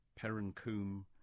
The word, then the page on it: Perrancoombe: English